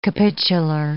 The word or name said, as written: capitular